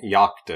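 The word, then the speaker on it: Jakt: And then there's also Jacked. Yacht. – I think Yacht, I think, is technically how it's supposed to be pronounced.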